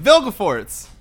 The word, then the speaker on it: Vilgefortz: I always pronounce his name Roggeveen. It's like her lover. – Oh! – How do I pronounce his name? – Vilgefortz. Vilgefortz, man.